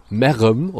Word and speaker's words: Merrem: Also erstmal wird es auf Kölsch wieder so richtig geil ausgesprochen. Merem, oder? Also es wird M-E-R. R-E-M geschrieben dann.